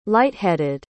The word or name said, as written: light-headed